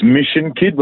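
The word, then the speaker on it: Myshon Kyd: It's Mission Kid, but it's spelt differently. So it's spelt Mission is spelt MYSHON. And then the kid is KYD. Yeah, so it looks, when you look at it, it's My Sean Kid.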